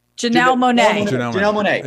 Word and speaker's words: Janelle Monáe: I'm going to say Janelle Monáe. Am I saying her name right? – Janelle Monáe.